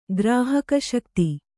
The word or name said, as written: ಗ್ರಾಹಕಶಕ್ತಿ